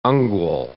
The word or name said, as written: ungual